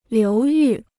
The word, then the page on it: 流域: liú yù